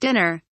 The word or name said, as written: dinner